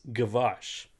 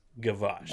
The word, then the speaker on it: Gwozdz: Another fellow Volkswagen guy, and his last name is GWOZDZ, but I think it's pronounced, and please correct me, I think it's Gavosh. – Wait a second. G-W-O-Z-D-Z. – Yes. Woods. That's not a typo. That's how he spells it, but I'm pretty sure it's pronounced Gavosh.